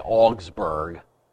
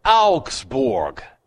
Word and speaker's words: Augsburg: Well, in English, we say Augsburg. It's Augsburg. A-U-G-S-B-U-R-G. Augsburg. What are these Anglo-Saxons going to stop mispronouncing good German words?